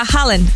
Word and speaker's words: Haaland: La inesperada cena que va a tener Tom Holland con el futbolista Haaland, Tom Holland con Haaland, básicamente, porque ya saben que se pronuncia igual.